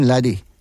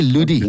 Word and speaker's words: Laddie: Growing up in the Western Cape, it was always an issue how people could not actually pronounce the surname Laddie. You know, they always used to say Ludi. Okay. Then I always used to say to those out there, but can you spell the word buddy?